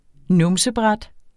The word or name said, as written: numsebræt